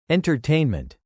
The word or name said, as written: entertainment